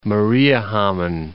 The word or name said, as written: Mariehamn